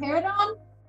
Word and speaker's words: Paridon: Is it Paridon? – Paridon, yes.